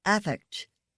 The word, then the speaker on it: affect: Affect. A noun with the accent on the first syllable.